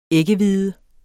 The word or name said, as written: æggehvide